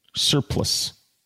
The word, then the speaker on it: surplice: How do you pronounce it? Surplus. Surplus. So like the other word, surplus.